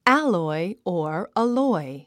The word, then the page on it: alloy: ǽlɔi; アロォイ